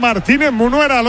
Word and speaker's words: Martínez Munuera: Ha pitado Martínez Munuera.